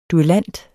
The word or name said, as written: duellant